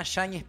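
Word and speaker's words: Yáñez: Vincent, qua ci servirebbe il buon Mariano, Vincent Yanez Pinzon. – Pinzon. – Sì, con la Y, no? Alla pronuncia argentina Shañez Pinzón. – Shañez. – Shañez. Perché c'ha quella N con, no? – Esatto.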